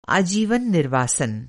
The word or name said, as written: निर्वासन